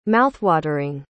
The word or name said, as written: mouthwatering